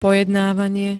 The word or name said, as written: pojednávanie